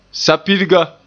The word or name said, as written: sapirga